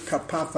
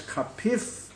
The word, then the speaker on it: kapaf: As far as kapaf goes, as I told you, I pronounce it kapaf, I find it hard to change. That's what I heard when I was a kid in yeshiva. Nevertheless, Jastrow has kapif, and I think that in Steinsaltz I saw kapaf.